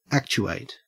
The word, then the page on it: actuate: Australian English